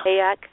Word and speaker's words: Hayek: This first one is from Selma Hayek. Am I saying her name right? Selma Hayek? – Hayek. – The actress? Hayek.